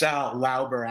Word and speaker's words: Lauber: It's Lauber, actually. – Lauber, okay. You Americanized it.